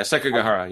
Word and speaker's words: Sekigahara: Sekigahara, the one that takes place in medieval Japan. – Oh, yeah, Sekigahara.